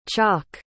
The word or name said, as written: chok